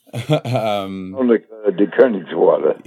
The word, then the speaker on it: de Koenigswarter: De Koenigswarter.